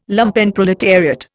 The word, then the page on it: Lumpenproletariat: Indian